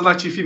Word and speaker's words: Latifi: Mas, mas essa Nicolas é, é, é, francês do Quebec, seria Nicolas a pronúncia é Nicolas Latifi mesmo.